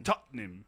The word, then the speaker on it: Tottenham: decimos, en vez de decir Tottenham, decimos Tottenham. Tottenham. Pues. – Tottenham. – Son dos sílabas.